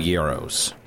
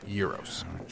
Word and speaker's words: gyros: I'm looking through the Y's, and I can't find gyros. – It starts with a G. – Oh, I love that. – You would want to pronounce it gyro, but it's actually euros.